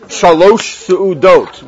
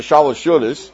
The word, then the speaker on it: Shalosh Seudot: How do we say it in Mama Lashon? Shalosh Seudot, which got slurred and Yiddishized into Shalosh Shudas.